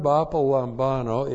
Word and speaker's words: apolambano: Apolambano, A-P-O-L-A-M-B-A-N, long O, apolambano. All right. One, the verb apolambano is a compound word made up of the preposition apa, there you have it, apa, plus the verb lambano.